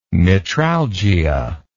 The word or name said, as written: metralgia